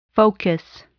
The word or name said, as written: focus